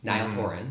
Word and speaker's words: Niall Horan: Niall Horan.